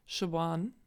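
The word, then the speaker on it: Siobhán: Genauso wie ähm, Shaban. Shaban. – Kannst auch gar nicht schreiben quasi. Shaban wird geschrieben Siopan.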